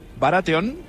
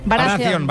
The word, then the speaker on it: Baratheon: És que feia estona que veia un rei, o sigui, és el vestit d'un rei, ara ho pronunciaré fatal, Joffrey Baratheon. No sé. – Baratheon, Baratheon.